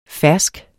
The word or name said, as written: fersk